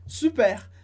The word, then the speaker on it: super: A gente usa muito em francês, super, pronunciando R, porque é uma palavra que vem do inglês, super, tipo, que legal, ótimo.